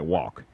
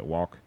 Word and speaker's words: Wauck: And the writer's name is Mark, forgive me if I'm pronouncing the name wrong, but it looks like Walk, W-A-U-C-K, so Walk.